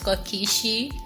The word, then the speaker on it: Kiishi: _ Kishi, KIISHI.